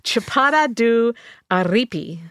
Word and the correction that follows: Araripe: No, I got it wrong. – No? – Araripe. Oh. Araripe. I left a syllable out.